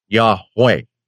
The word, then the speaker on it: Yahweh: Part one, which has to do with the Tetragrammaton, those four little markings that are the Hebrew letters which are pronounced Yahweh.